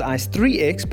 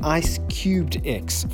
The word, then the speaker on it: ICE3X: A lot of people pronounce it Ice3X, but I have got confirmation from the CEO, Gareth Hrobler, also the founder, that it is indeed Ice Cubed X, for those of you wondering.